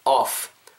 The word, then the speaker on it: off: 그래서 반드시 입술이 닿으면 안 된다는 겁니다. off, turn off, turn off 다르죠.